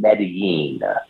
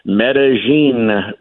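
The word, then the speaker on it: Medellín: And real quick, so that I don't look like a country rube who's an idiot down here, I've been saying Medellin, like a Y, Medellin, Colombia. But you and a couple of other people have pronounced it differently. So what are you saying? – So remember one time you corrected me on Bowie versus Bowie, Maryland? I will correct on Medellin with a J, Colombia.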